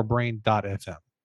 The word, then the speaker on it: Brain.fm: Is the proper way to say it, is it Brain FM or Brain.FM? – Whatever you prefer. We internally say Brain.FM. – Okay. – But either way, people can tell.